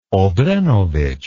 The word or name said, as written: obrenovic